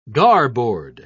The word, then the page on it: garboard: British English